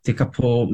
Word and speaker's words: Tekapo: And then there's this adventure playground at Lake Tekapo, T-E-K-A-P-O. Tekapo, is it? Is that how it's pronounced? – That's right.